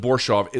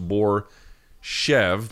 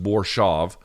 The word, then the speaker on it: Borshov: They pronounced it Borshov. It looks like it's pronounced Borshev, but I guess it's just Borshov.